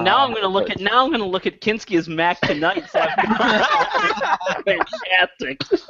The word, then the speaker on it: Kinski: Kinski.